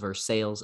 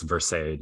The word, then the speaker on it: Versailles: That's why it's Versailles, not Versailles. – Yep. Versailles, if we're being phonetically inclined to our American friends there.